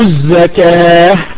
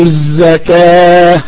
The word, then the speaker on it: الزكاة: طيب يا عبد الرحمن انتبه في قول الله تعالى ويؤتوا الزكاة، عندما تقف انطقها بالهاء ويؤتوا الزكاة، تمام، ويقيموا الصلاة ويؤتوا الزكاة بالهاء، لما تقف عليها قف بالهاء، لك الله قط يا عبد الرحمن.